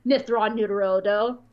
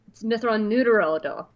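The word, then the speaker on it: Mitth'raw'nuruodo: And Anakin goes, I know how to pronounce long names, Mithron Utarodo. No, no, you're saying it wrong. It's Mithron Nuterodo.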